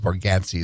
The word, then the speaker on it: Bargatze: There's a comedian, one of my faves, Nate, I believe it's Bargatze is the way you pronounce it.